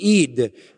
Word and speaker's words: ed: If you transliterated it, it would be actually ed. It would be a long E and a D with an H kind of softening the D in Hebrew.